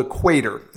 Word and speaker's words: Cuater: Everybody knows the brand Travis Matthew, and this is a shoe called the Quater, except it's spelled C-U-A-T-E-R. So hard to spell, hard to pronounce, not hard to wear, and not hard to absolutely love.